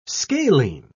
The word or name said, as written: scalene